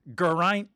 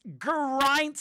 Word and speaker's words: Geraint: I've been pronouncing his name Geraint, like he had some French blood, Geraint, but I've heard that he's Welsh. He's Welsh, and he says, it's Geraint, Sonny.